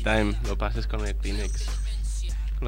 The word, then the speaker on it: Kleenex: Kleenex.